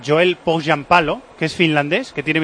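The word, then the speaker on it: Pohjanpalo: Poggianpalo se llama así. – Poggianpalo. – Poggianpalo se escribe. – Ah, Poggianpalos. – O sea, Poggianpalo. – Sí, señor. Así se pronuncia.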